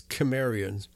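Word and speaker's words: Cimmerian: Okay, I prefer to use the Greek pronunciation, which is Kimmerian. And the reason why is it's too easily confused with the term Sumerian. So Sumerian and Sumerian are so close that we might as well stick with the Greek pronunciation of Kimmerian.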